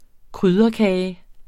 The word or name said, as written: krydderkage